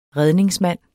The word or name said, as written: REDNINGSMANDEN